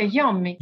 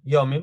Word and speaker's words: Iommi: pronuncerebbe Iommi, no? – Iommi.